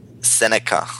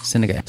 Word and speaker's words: Seneca: How do you pronounce the person who's wrote that quote again? – Seneca. – Seneca.